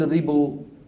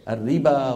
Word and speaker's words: الربا: نحو الألف المكتوبة في واو الصلاة والزكاة والربا، ما أحد يقول الربو ولا أحد يقول الزكو، كله شو بيقول؟ الربا والزكاة.